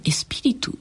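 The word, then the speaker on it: espíritu: エスピリトというのはこれ発音はどういう発音なんですかスペイン語ではエスピリト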